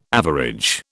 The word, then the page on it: average: British English